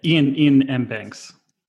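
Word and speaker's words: Iain M. Banks: Ian M. Banks. I think you said Liam by another name mispronunciation.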